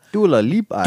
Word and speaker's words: Dua Lipa: Best New Artist, the winner was, and forgive me if I'm butchering this name, Dua Lipa? – Dua Lipa.